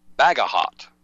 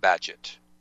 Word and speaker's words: Bagehot: And it's spelled B-A-G-E-H-O-T, sort of like Bagahot, but it's pronounced Badgett.